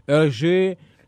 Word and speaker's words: Hergé: Y que me mienten la plana los francófilos y que me digan, no, sapo, se pronuncia Hergé, se pronuncia Hergé.